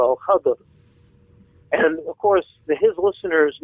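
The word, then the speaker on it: Al-Khadir: Now, a very important part of the Hadith transmission about this story is also the very name, Al-Khizr, which in more classical Arabic of the Hadith is pronounced Al-Khadr and voweled differently, so it's spelled K-H-A-D-I-R rather than the common K-H-I-D-R or K-H-I-Z-R.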